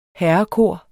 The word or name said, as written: herrekor